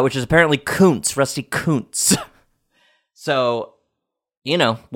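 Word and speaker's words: Kuntz: I made sure to look it up, and I looked it up, and it's pronounced Koontz. Rusty Koontz, not Rusty Koontz, which is how it's spelled and how you would think it was spelled.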